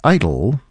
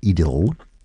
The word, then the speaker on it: idyll: An idyll, or if you prefer the English pronunciation, an idyll.